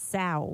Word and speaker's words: Sao: If you're going to be finicky about it, it's got to be pronounced the Sao Biscuit. – Sao Biscuit. – As in cow with an S, Sao.